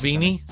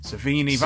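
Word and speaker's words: Sevigny: Chloe, I can never pronounce. – Savini. – Savini. That's how you pronounce it.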